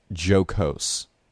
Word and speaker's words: jocose: I'm going to redo that. It's jocose.